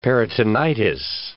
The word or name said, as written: peritonitis